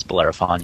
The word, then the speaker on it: Bellerophon: Did I say it? Bellerophon? – No, I've always pronounced it as Bellerophon, yeah.